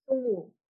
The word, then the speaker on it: Sungwoo: Sungwoo? – Sung? – Ooh. – Oh, yes. When it's a G before the W, you don't pronounce it the W. So it's Sungwoo.